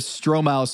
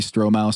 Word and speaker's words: Stromile: You know, George Sedona used to call me Stromile Swift. – Why? – Stro. – Oh, Haber Stromile. – Wow. – If you go to Basketball Reference right now, the pronunciation guide says in capital letters, S-T-R-O-H.